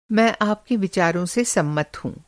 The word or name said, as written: सम्मत